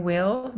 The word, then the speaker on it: Wil: It's APRYLL and then Will, WIL.